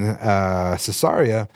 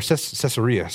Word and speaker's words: Caesarea: And also, we're going to see in a moment when he lands in Caesarea, it's going to have, or Caesarea, sorry, pronounce that wrong all the time, Caesarea as well, that it's going to be the same situation, except for it's a little more clear.